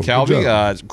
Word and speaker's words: kalbi: You made some kalbi? Is that how you pronounce it? – Yeah, there we go. – Kalbi, cross-cut beef ribs.